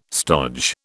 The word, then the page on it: stodge: British English